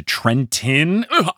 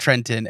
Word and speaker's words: Trenton: There is a point where Walker Scoble, as Percy Jackson, says they haven't even made it to Trenton. My New Jersey. Soul, you can't pronounce the second T in Trenton.